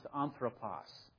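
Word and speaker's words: anthropos (ἄνθρωπος): This word would be pronounced anthropos.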